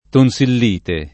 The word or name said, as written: tonsillite